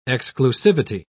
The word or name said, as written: exclusivity